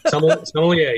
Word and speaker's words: sommelier: And I love, love, love for you to expand on the sommelier kits. I did say that right, right? Sommelier? sommelier, yes, sommelier.